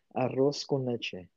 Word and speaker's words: arroz con leche: Arroz con leche.